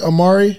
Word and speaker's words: Omari: Am I pronouncing it correctly? Omari? – Yeah, you pronounced it correct.